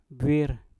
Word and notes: bwir: Mpur

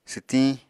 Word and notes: swtiñ: apatani

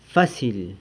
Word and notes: fasily: sanlucasquiavini

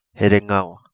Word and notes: Herengua: Kapingamarangi

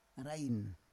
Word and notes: arain: Roon

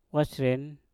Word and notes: wasren: mpur

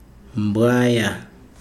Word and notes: mbwaya: Chikunda

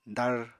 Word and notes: dar: apatani

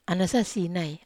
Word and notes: ana sasinai: Wamesa

